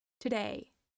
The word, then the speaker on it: today: But it's important to keep in mind that the first syllable in to, to is a schwa sound. The stress is in the second syllable. Today, today, today. So the first sound is a very fast schwa sound, t, and the long syllable is the last one, the one about day.